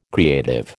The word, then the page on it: creative: American English